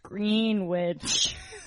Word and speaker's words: Greenwich: And it's pronounced Greenwich.